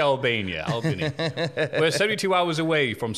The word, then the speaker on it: Albany: In Albany, New York. – Albany. – Wow, that's really bad. I had no idea how to fucking pronounce that. – Albany. – Albania, New York. – Not Albania.